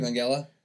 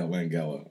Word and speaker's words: Langella: How do you pronounce this guy's name? – Frank Langella. – Yeah, Langella. Okay. – Langella. – I was going to say Langella. Jillian Jacobs.